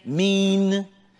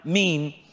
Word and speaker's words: miyn: Is pronounced like M-E-E-N, mean, but it's spelled M-I-Y-N, but it's pronounced mean.